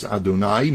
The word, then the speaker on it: Adonai: which is Adonai, means that God is master of all, because the word Adonai means Adon, the master.